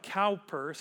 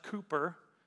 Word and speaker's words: Cowper: His name looks like Cowper, C-O-W-P-E-R, pronounced Cooper.